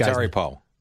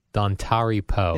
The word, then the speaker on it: Dontari Poe: Dontari Poe. – Dontari Poe.